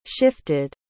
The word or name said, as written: shifted